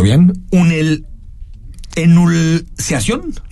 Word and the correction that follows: Enucleación: Enucleación. Enucleación.